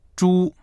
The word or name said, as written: zhu1